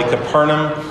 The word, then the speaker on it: Capernaum: Capernaum, is how you pronounce it.